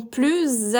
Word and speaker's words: plus: Como é uma negação, a gente não pronuncia o S de plus. Então aqui a pronúncia é com o U biquinho do francês e o S mudo.